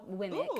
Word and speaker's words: WIMK: I had like a clothing brand called WIMK, which is like my initials pronounced out. WMK. So it was called WIMK.